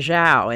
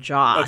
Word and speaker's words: Zhao: I used to say Zhao and now it's Ja. Okay. So we all collectively learned in the past 2 weeks that that's how it's pronounced.